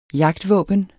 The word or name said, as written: jagtvåben